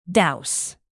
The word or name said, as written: douse